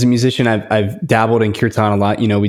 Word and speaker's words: Kirtan: Have you done any work with mantra or curtain, Kirtan, I think it's pronounced. – Yeah, yeah, Kirtan.